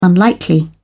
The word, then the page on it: unlikely: British English